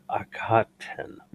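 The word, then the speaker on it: Akhaten: The rings of Akaten. – Yes. Yes. – I always want to put an extra syllable in that word, and I know it's not there.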